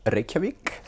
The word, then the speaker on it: Reykjavik: Have I pronounced that correctly? – Yeah, close enough. Reykjavik. – Oh, God, I'm under so much pressure. Reykjavik.